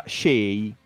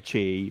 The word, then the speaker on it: Che: Eh, lui però dice che lo pronuncia Shea più che Chey, perché vabbè. Sono, Quindi è Shea Adams.